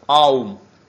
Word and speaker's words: Аум: Он говорит, этот слог «Ом», он более полно произносится как «Аум».